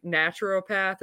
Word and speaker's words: naturopath: You know, like your holistic doctors, your functional doctors, you always see naturopath? Is that how you say it, Michelle? – Yes, it is.